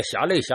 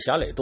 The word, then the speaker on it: 侠累: 叫侠累，大侠的侠，这个连累的累，其实念侠累侠累都对，这多音字。